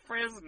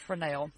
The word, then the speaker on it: Fresnel: His last name is pronounced Fresnel. – It's not. It's pronounced Fresnel.